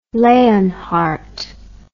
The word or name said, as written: leonhardt